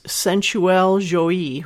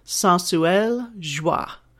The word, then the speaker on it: Sensuelle Joie: So the name of this amazing bullet that is cheaper than the Wii Vibe Tango is, it's two French words, right? So I'm going to say them like the American way. It's sensuel joie, right? But pronounced in the French way, as best I can, it's sensuelle joie.